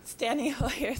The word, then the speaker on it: Steny Hoyer: Well, on the day that we went to Congress and we disrupted Rahm Emanuel, and I think Kenny Stoyer was there too, or did I say his name right? That didn't sound right. Anyway. – Steny Hoyer. – Steny Hoyer, thank you.